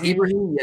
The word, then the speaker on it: Ibrahim: I think it's pronounced Ibrahim, yeah.